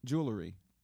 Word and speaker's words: jewelry: Jewelry. That's the right way to say it. – Okay. It's L-R-Y at the end, right? – L-R.